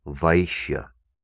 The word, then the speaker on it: Vaishya: Kshatriya is spelled K-S-H-A, Ksha, Triya, T-R-I-Y-A, all one word, Kshatriya. The next caste is Vaishya, V-A-I-S-H-Y-A, Vaishya, Vaishya.